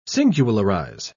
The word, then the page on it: singularize: British English